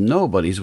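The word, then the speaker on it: Knobodies: He's got a book coming out from Kickstart, one of my very favorite companies, and it's called Nobodies with a silent K at the beginning.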